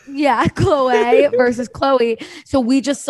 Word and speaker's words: Khloe: That Khloe is, by the way, that is how you say her name. She like, on the reality TV show by Bravo Andy, they were interviewing her and he says Chloe and she's like, thank you. That's the correct way to say my name.